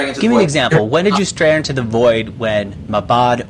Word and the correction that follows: Mahbod: or, am I pronouncing his name right? Mabod? – It's tough. Mahbod.